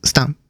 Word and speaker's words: STAVN: ジャーピーとスタン、 STAVN っていうスペル表記なんですが、ちょっとこれ発音が難しくて、実況を聞いてると V があまり聞こえず、スタンという風に自分は聞こえたりするんで、まあちょっとそういう風に今読みました。